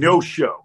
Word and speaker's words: Neosho: So you're from Neosho, Missouri. I'm pronouncing that correctly, correct? Neosho. Okay. Mm-hmm. No show, no show, Missouri.